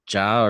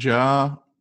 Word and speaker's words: Ja: Yeah, so it's pronounced Ja, and it's not two initials. It's not like J.A. Happ or anything. It's actually Ja.